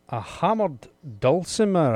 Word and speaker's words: hammered dulcimer: Now, Patricia, your second item that you would like to take with you is a hammered dulcimer.